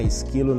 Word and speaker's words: Szklo: Esquilo não é esquilo com, como se fosse do animal comum. O esquilo se escreve S-Z-K-L-O, tá?